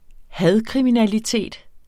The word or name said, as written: hadkriminalitet